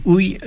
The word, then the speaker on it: uj: Ui, ui, donc UG, UJ. Ui, donc on prononce ui, désigne un contenant, un réservoir.